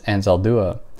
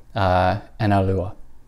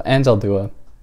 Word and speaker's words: Anzaldúa: And I did all this work trying to find out how to pronounce Anzaldúa, and I was consulting all of these Spanish sources, but the problem was I was consulting like Spanish Spain sources who pronounced her name something like Analúa.